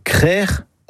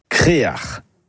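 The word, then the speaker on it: creac'h: Le mot breton, c'est créar. Et ce mot, on le retrouve dans des hameaux ou des quartiers, même pour un phare. Et la bonne production est crère ou Créard. Créard, c'est une prononciation plus du Nord Finistère, mais on la trouve parfois ailleurs.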